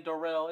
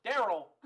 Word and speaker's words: Daryl: He's still over here saying Darrell. It's Daryl.